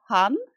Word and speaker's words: hon: Well, and also the way it's spelled, H-O-N, mm-hmm. I want to say hon.